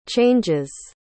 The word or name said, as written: changes